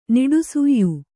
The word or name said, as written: ನಿಡುಸುಯ್